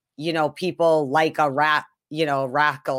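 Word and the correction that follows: Rakell: or whatever, or Rake, what's his name? Raquel. Sorry, Raquel. I said his name wrong.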